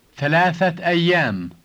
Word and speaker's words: ثلاثة أيام: Notice that when you say the two words together, you should add a T sound at the end of the word ثلاثة for three.